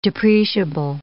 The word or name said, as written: depreciable